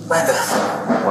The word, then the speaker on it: Lampeth: Lampeth. – What? How do you spell it? – L-A-M-P-E-T-H. – L-A-M-P-E-T-H, okay.